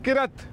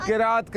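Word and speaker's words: قراءت: دیکھیں کچھ بچے کہتے ہیں قرت, قرت کرنا, سنا ہو نا قرت کہتے ہیں, قرت غلط ہے. اصل تلفظ کیا ہے اس کا؟ قرات کرنا.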